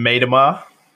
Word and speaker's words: Miedema: Vivian Miedema.